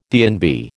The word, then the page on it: DNB: American English